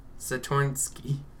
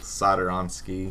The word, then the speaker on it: Satoransky: I'm going to say Satornsky. – No, it's pronounced just like it looks. Satoransky.